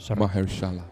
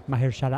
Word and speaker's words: Mahershala: y con el Blade de Mahershala Ali eh, que no tengo idea cómo se pronuncia. – Mahershala. – Mahershala Ali.